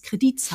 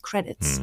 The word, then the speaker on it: Credits: Oder dass es Kredits heißt und nicht Credits.